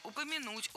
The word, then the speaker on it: упомянуть: То, что завод «Москвич», которому в прошлом году упомянул, кстати, исполнилось 80 лет, в общем, это серьезная дата. – Было сказано «упомянуть», «упомяну», но это неверно. Следует говорить «упомянуть», «упомяну». Ударение в этом глаголе ставится на последний слог.